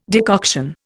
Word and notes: decoction: Indian

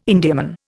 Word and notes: Indiaman: Indian

